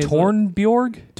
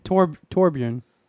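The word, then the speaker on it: Torbjörn: How do you say that man's name? – Is it Torbjorg? – Torbjorn. – Torbjorn.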